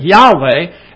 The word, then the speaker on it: Yahweh: And some pronounce it Jehovah, and others pronounce it Yahweh, and they're putting different vowel points in there.